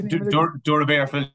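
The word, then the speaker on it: Doora Barefield: You were at Clare years ago as well, playing with a Clare club called, correct me if I'm wrong pronouncing this now, St. Joseph's Dura Bearfields. I think it's Dura Bearfields. Yeah. St. Joseph's Dura Bearfields, spot on.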